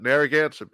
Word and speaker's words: Narragansett: I never say it right. It's from New England. Narragansett. – Oh, Narragansett. Narragansett beer.